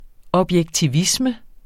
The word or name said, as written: objektivisme